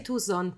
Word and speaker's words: Tucson: Ma si dice eh. Tucson però. – Tucson. Eh, no, si scrive no. Tucson.